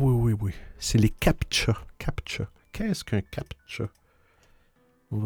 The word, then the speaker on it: CAPTCHA: Un CAPTCHA, on se prononce ça, C-A-P-T-C-H-A, un CAPTCHA,